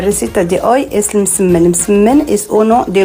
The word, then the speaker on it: Msemen: Msemen, yo que sé, en otros lugares se conoce como ergaif. Es una palabra complicada, decías M-S-E-M-E-N. Msemen, msemen, vale. Y se pronuncia. Pero nunca, nunca se pronuncia como lo vamos a escuchar en boca de este tiktoker, porque puede ocasionar confusiones.